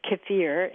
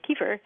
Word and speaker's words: kefir: Like, you know, we also recommend taking our kefir starter and putting it into milk and drinking the Russians pronounce it kefir, and yes, we say kefir.